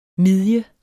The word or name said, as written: midje